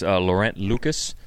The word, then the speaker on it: Laurent Lucas: Yeah, and this one you may recognize Laurent Lucas. – Yeah. – Is that how you pronounce his name? – Yes.